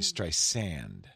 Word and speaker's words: Streisand: It should be Streisand. – Streisand. – So she called Tim Cook. And she says, Streisand with a soft S like sand on the beach.